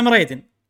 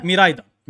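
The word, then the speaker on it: Miraidon: اذا بقول النطق الصح الياباني خلينا نقول او مو الياباني لان هم مستوحى من كلمه يابانيه عشان كذي بقول النطق اقرب للياباني ان اللي البرتقالي اللي بسكارلت اسمه كورايدون واللي بالفايلت اسمه ميرايدون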